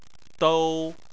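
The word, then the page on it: dou: tone 1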